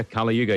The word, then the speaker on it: Kali Yuga: So, Kali Yuga, am I pronouncing it right? – Yeah, Kali Yuga.